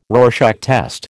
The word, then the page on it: Rorschach test: American English